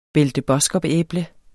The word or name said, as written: belle de boskoop-æble